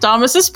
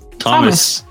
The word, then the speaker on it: Thomas: Thomas. Yes, Thomas. – You don't need to pronounce the H.